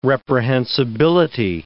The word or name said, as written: reprehensibility